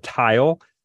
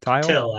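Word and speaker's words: Tyll: His other translations include Frederick Holderin's Hyperion, Joseph Roth's Job, Daniel Kelman's You Should Have Left, and Tile. Am I pronouncing that right? – Till. – Okay. – It's with a Y, so I can see how you could.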